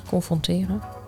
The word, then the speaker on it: confronteren: Nee, zeg ik het goed? Confronteren. Confronteren. Ja.